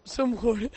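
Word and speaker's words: Džumhur: Bueno, pues tú, ¿cómo lo dirías? Sumhur. – Sumhur. – Está bien. Está bien pronunciado así.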